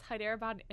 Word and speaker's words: Hyderabad: Hyderabad, Kolkata? – It's Hyderabad, and it used to be Calcutta, and now it's Kolkata.